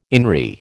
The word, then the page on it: INRI: American English